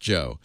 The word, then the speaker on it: Juan: Spelled Juan, but it's pronounced Joe.